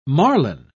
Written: marlin

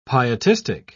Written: pietistic

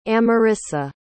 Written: amarissa

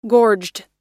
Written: gorged

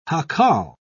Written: jacal

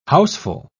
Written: houseful

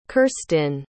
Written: kirstin

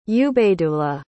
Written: ubaydullah

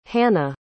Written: hannah

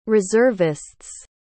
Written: reservists

